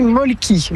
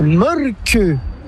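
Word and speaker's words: Mölkky: Inventé en Finlande en 1996, ce jeu de quilles s'écrit M-O-T-R-E-M-A-L-K-K-Y. – Ça s'écrit molki, mais ça se prononce molke. – Le molki. – Plutôt un E qu'un E, mais pas un U. Donc ça fait molke.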